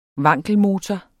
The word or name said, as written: wankelmotor